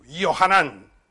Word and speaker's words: Yohanan: Because in Hebrew, you said, Yohanan. This is how you say it in Hebrew. Yohanan. With two N's.